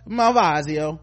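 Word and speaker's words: Malvasia: This custom wine pack sweet features two bottles of sparkling wine, the Just Peachy Refreshing Bubbly from France, and the Cantina de Cazorzo Malvasio. Mm-hmm, that's how you say it.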